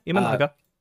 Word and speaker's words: Imanaga: We had Merrill Kelly for the US versus Imanaga. I may have butchered that to no end for Japan. – Yeah, Imanaga.